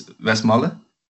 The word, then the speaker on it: Westmalle: One of them is, I'm going to pronounce it in a Dutch way, but it's Westmalle.